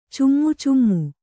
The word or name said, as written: ಚಿಮಿಚಿಮಿ